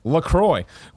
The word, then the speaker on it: LaCroix: It says, hibiscus, LaCroix sparkling water, pronounced LaCroix, was developed to give health-conscious consumers refreshment, flavor, and sparkle with an innocent twist of zero calories, zero sweeteners, and zero sodium.